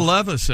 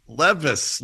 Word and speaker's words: Levis: The amazing Will Levis, everyone. – Wow. – Yeah. – Levis looked great, comfortable like an old pair of Wranglers, you might say. – Yeah, he's, Levis? Spelled like Levi's. That's very good.